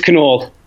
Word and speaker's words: Knoll: Have I pronounced that correctly? Knoll or Knoll? How do you do it? – It's Knoll. – Knoll. – Yep. – Cool. – So it looks like Knoll, and in American English, it's, you know, people say Knoll, and but it's Knoll.